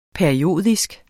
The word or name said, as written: periodisk